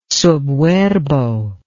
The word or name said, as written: sub verbo